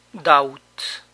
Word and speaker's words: doubt: Doubt. Doubt. Aquí tampoco se pronuncia la B.